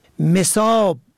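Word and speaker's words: مِصاب: مساب به فتح اول جمع مسب به معنای محل مورد موضع، مساب به کسر اول به مفهوم سیراب پر و فرد سیراب و پر شده از آب.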